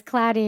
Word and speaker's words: Klaty: Before it was, I thought that's what your name was, Klaty.